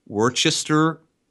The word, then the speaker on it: Worcester: So what I have here is from 12/7/98 from the Centrum in, I said this wrong last podcast, Worcester, Massachusetts.